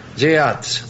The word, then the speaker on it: Yeats: Bueno, sí, yo lo pronuncié mal. No sé cómo se pronuncia. – No, pero no es fácil. – Yeats. – Yeats. – Bueno, muy bien. Camilo Yeats. – De Córdoba. Sí, exacto. – Yeats, así es.